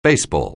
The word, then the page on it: baseball: anglais